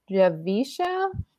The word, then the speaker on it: Javicia: and I believe her name is pronounced Javisha Leslie. – Yeah. Javisha.